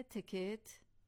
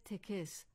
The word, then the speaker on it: تکت: ولی این کلمه گاه توسط مردم به صورت تکس تلفظ می شود. اینجا دیگر می توان هشدار داد و توصیه کرد که آن را درست ادا کنید.